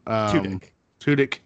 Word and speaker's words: Tudyk: Tudyk.